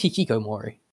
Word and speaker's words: Hikikomori: I'm pretty sure it's Hikikomori. – Have I just been blanking on a syllable?